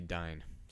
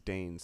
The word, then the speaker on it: Dain: But it also might be Dine. So I I, w- I looked up how to pronounce it, and all I could find was Dane.